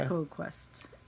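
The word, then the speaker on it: EcoQuest: Echo Quest. – And they pronounce it Echo, so I'm like, oh, crap, that's not how you're supposed to say that.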